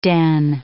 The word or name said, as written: den